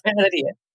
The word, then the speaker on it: Ferhadija: Is it Ferhadija. – That's right. Yeah, you're close enough. Ferhadija.